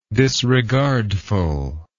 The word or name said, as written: disregardfulless